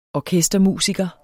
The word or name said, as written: orkestermusiker